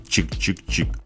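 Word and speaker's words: !!!: А наш обзор завершает диско-панк-ансамбль с танцевально-авангардным уклоном из калифорнийского города Сакраменто, название которого состоит из трех восклицательных знаков и произносится как «чик-чик-чик».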